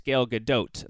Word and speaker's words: Gal Gadot: Is it Gal Gadot? She's like, it's Gal Gadot, and I'm like, okay, now I know me.